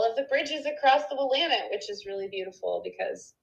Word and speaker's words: Willamette: And I knew someone who went to Willamette High School and told me that their cheerleading squad used to cheer, it's pronounced Willamette, damn it.